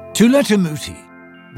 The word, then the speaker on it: Tula Tamuti: Tula Tamuti, rhymes with tool in the booty, he tells people,